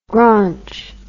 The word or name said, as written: graunch